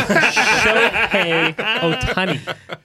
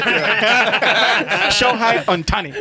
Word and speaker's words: Shohei Ohtani: That's you're like, that's like signing Shohei Ohtani. – Yeah. – Shohei Ohtani. – Oh, now you're telling me how to say it? Shohei Ohtani.